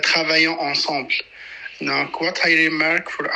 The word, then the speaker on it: ensemble: Ensemble.